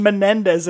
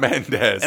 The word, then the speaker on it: Mendes: Keep saying Mendes and adding well, a full ass syllable. – It's Mendes.